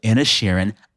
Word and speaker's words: Inisherin: The Banshees of Inisherin.